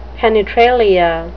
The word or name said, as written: penetralia